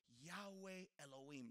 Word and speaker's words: Yahweh Elohim: In the original language, which is Hebrew, would be pronounced Yahweh Elohim.